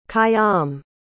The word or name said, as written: khayyam